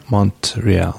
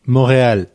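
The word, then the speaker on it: Montréal: Yeah, like if you're saying it in English, you would say Montreal. – Montreal. If you say it in French, you would say Montreal. Well, how would you say it? Montreal. The T is silent.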